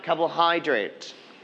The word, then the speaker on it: carbohydrate: One more time, carbohydrate. – Carbohydrate. – It's very good. We are going to stress on the third syllable, hydrate.